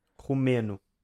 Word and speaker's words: romeno: Rumeno. – Oh my God. – R-O-M-E-N-O.